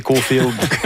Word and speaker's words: Caulfield: Ouais, c'est vous qui le prononcez comme ça. Vous et Guy Carbonneau prononcez Caulfield.